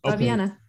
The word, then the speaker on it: Fabiana: Yes, Fabiana.